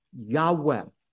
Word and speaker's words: Yahweh: And most Semitic scholars believe it's pronounced a breathy Yahweh.